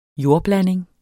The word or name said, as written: jordblanding